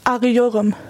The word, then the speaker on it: Arıyorum: Arıyorum.